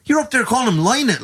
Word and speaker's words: Lynott: It's Lynott. Don't ever say Lynott. Phil Lynott, lad.